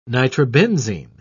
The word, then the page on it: nitrobenzene: British English